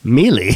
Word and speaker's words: melee: But no injuries were reported in the melee. This jockey Dahl has held two... – Melee? – Mm-hmm. Melee. – I mispronounced that word because I hadn't written the script myself, and it came...